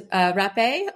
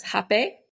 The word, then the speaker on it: rapé: And the last one is rapé, also pronounced hapé, because it's spelled R-A-P-E. It has an accent over the E, but that looks like a word that, you know, it's not good to type out on the internet.